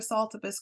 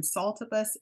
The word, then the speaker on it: Saltibus: And Saltibus is spelled salt i bus S A L T I B U S.